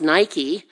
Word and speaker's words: Niko: His the first part of his name, Niko, which we would pronounce Nike, means victory, and Demos is people.